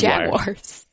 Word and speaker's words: Jaguars: Jaguars. Jaguars. It's not that hard. – Jaguars? – You're saying Jaguars. – I know. People get mad at me about this. – Oh, my God. – Jaguars?